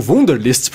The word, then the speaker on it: Wunderlist: é o Wunderlist, ou Wunderlist para as pessoas que gostam de falar a pronúncia certa dele, né? A patrulha do inglês, cara. Não, né? essa é a patrulha do alemão ou do holandês, sei lá.